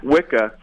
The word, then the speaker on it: Wicca: The word Wicca in modern English, they believe it comes from the Old English term that's pronounced Wicca, which was a reference to a male witch,